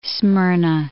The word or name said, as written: Smyrna